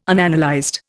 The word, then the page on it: Unanalyzed: Indian